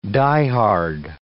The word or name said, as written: die-hard